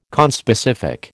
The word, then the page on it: conspecific: American English